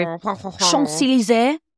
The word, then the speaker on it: Champs-Élysées: We're at Harry Winston Paris, where they store all the rare jewels of the world in the Golden Triangle districts of the, how do you say it? Champs-Élysées. – That is not how you say it, but I sounded very... Champs-Élysées.